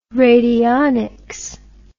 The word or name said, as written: radionics